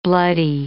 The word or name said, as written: bloody